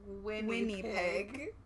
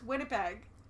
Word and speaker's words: Winnipeg: Guinea pig rhymes with Winnie pig. Which is not how you say that at all. It's Winnipeg.